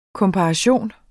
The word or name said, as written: komparation